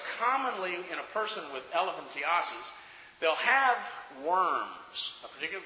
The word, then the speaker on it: elephantiasis: It might be what many people mistakenly call elephantitis, and really the correct pronunciation is elephantiasis.